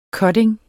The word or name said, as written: cutting